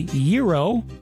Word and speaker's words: gyro: How do you say the word spelled G-Y-R-O? Some people say gyro, others say gyro.